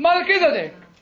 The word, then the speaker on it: Melchizedek: Melchizedek. Right. Melchizedek was a Gentile, actually. Not a Jew. A Gentile. Yes, a Gentile, yes. How do you say it in American? I said it correctly? Oh, thank you.